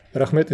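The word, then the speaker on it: Rahmet (Рахмет): Rahmet Siege, did I say that correctly? – Rahmet. Rahmet is okay.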